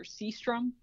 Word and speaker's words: Sjöström: So Victor Sjostrom, sometimes known in the United States as Victor Sjostrom, but I'm going for a more traditional Swedish pronunciation with that one.